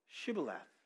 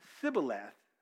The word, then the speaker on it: Shibboleth: And what would happen is the people of Gilead would test them, and they would ask them to pronounce the word Shibboleth. It's right there in Scripture. And if they pronounced it correctly, they would let them in. But if they pronounced it Sibboleth, then they killed them. Because the people from Ephraim couldn't pronounce that SH sound. They couldn't handle it.